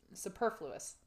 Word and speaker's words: superfluous: There's not an R. Superfluous.